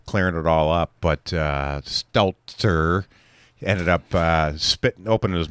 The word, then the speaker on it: Stelter: Well, Stelter, don't say it wrong. He's not a Seltzer. He's a Stelter.